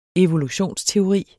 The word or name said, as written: evolutionsteori